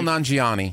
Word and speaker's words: Kumail Nanjiani: I think possibly the worst thing was listening to, and I'm going to say this name wrong, is it Kumail Nanjiani? – Yeah, Kumail. – Kumail Nanjiani.